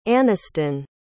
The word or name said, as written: Aniston